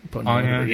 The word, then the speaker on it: Ponyo: Ponyo? – Ponyo, yeah.